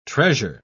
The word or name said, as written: treasure